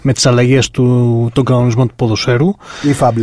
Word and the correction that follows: IFAB: λέγεται. Ναι. Νομίζω iFab. Ε, ναι, αυτό. – Ε, α, όχι, ναι. το, επειδή το. Εγώ με την το ε, ε, ελληνική σεραϊκή προφορά το, το είπα.